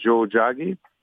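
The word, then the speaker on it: Tiohtià:ke: En fait, on remplace le T par un J, fait que ça fait Joe Jagi.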